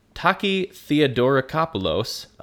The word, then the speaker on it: Taki Theodorakopoulos: By 2018, in July, the Greek journalist Taka Theodora... Taki Theodorakopoulos said...